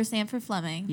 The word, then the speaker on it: Sandford Fleming: Oh, we are going to talk about the inventor of standard time and time zones, Sandor Fleming. – My boy, Sir Sandford Fleming. – Yeah. – Love that guy. – Also said his name wrong right off the top.